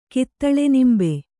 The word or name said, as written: ಕಿತ್ತಳೆನಿಂಬೆ